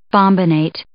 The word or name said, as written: bombinate